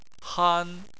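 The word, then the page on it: han: tone 1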